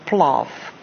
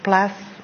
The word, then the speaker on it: Plath: En natuurlik in dieselfde dorp in Amherst, Massachusetts, is daar natuurlik ook Smith College waar die ander groot digter Sylvia Plath gebly het. Wat die Amerikaners sê Plath, I say Plath.